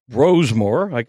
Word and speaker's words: Brosmer: And it's pronounced Brosmer. I don't have a huge issue for people saying Brosmer. Max Brosmer is in for J.J.